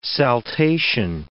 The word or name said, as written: saltation